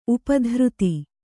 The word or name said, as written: ಉಪದ್ರುತ